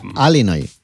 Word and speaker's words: Illinois: Se pronuncia Illinois.